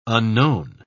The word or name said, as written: unknown